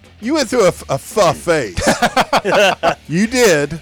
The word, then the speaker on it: pho: That'd be P-H-O. You seem fe? – Pho is P-H-O is pronounced pho.